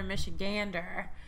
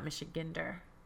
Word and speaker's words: Michigander: So another Michigander. – Yes. – On the show today. I learned that's how you say it, not Michigander.